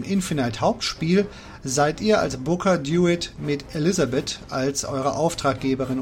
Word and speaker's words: DeWitt: Aber natürlich hören sich Booker DeWitt, ja im Englischen wird er so ausgesprochen, nicht Herr DeWitt, und Elisabeth im Original ebenfalls klasse an.